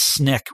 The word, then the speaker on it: SNCC: My favorite was a group called SNCC, which is usually pronounced SNCC, which stood for Student Nonviolent Coordinating Committee.